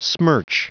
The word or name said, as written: smirch